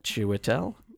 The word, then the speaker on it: Chiwetel: Chiwetel.